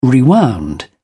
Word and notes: rewound: British English

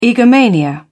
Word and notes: egomania: British English

